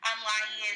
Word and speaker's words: Laryea: Laryea is LARYEA.